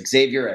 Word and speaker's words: Xavier: I think that's the right American pronunciation. Is that correct? Let's start. Xavier. Is that right? – Yes, Xavier Edwards.